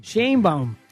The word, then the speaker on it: Sheinbaum: Pero ya habló de ella la presidenta Sheinbaum, como le dicen algunos, porque no saben pronunciar su apellido. Sheinbaum.